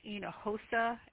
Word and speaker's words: Hinojosa: The inspector's name is Ryan, R-Y-A-N. Last name is pronounced Hinojosa, H-I-N, like Nancy, O-J, like John, O-S, like Sam A, looks like Hinojosa.